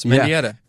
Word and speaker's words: Mendieta: Yes, Mendieta.